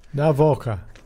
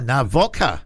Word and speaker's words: Gavoka: Among the delegation will be Fiji's Deputy Prime Minister, William Gavoka. – Navoka. The G is pronounced as an N. – Really? – As in Noki. – Oh, Navoka.